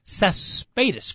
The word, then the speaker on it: Cespedes: Cespedes. Great email from Juanita Chiquita. – You actually did a real good job pronouncing that name.